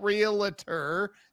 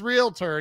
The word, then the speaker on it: realtor: I'm like, there's no vowel between the L and T. Why are you doing that? It's not realtor. It's realtor. And you're saying it right.